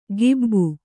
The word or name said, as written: ಗಿಬ್ಬು